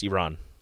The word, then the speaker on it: Iran: It's not Iran, I believe. That's how it's pronounced. – Oh. – One of the U.S. players were corrected by an Iranian reporter. – Did he say Iran? – Yeah, they said Iran, and he said that's not how you say it. He said it's pronounced, I believe it's pronounced Iran. So.